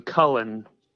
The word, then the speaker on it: Cullen: Not Cohen, Cullen. – How do you spell that? C U L L E N.